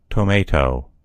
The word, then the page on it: tomatoe: American English